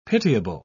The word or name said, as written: pitiable